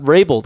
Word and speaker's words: Vrabel: Belichick got Vrabeled, because we always say, oh, they got Belichicked. Right. Belichick got Vrabeled. Mike. Thank you. I said it correctly.